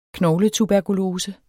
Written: knogletuberkulose

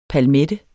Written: palmette

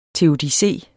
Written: teodicé

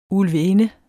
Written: ulvinde

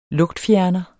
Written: lugtfjerner

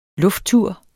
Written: lufttur

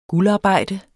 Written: guldarbejde

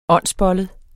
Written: åndsbollet

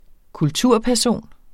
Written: kulturperson

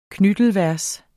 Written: knittelvers

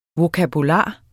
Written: vokabular

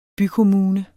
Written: bykommune

